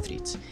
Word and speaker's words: Threads: porque se pronuncia threads,